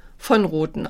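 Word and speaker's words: von Roten: Und über Iris von Rothen und ihren Mann Peter von Rothen, wobei ich weiß, ich sag das immer ein bisschen falsch, in der Schweiz wird es eigentlich von Rothen ausgesprochen, also wird es von betont.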